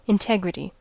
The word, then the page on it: integrity: American English